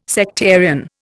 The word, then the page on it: sectarian: Indian